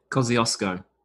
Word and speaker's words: Kosciuszko: How do you say it? – Kosciuszko. – Yeah, we say Kuzco, I think.